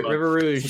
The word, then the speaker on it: River Rouge: Is that how you say it? River Rouge. – Stank on it. Yeah, that's right.